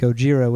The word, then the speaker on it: Gojira: 1954, the film Gojira, which is how we say it in American, it is a combination of the Japanese words for gorilla and whale. You get Gojira, which they say something like this.